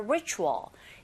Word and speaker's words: ritual: Ritual 발음하실 때 R 사운드 처음 소리 내실 때 잘 안 되시면 힌트는요. 우 하면서 re 하시면 돼요. Ritual. 그런데 이때 혀는 어디에도 닿지 않게 발음하셔야 됩니다.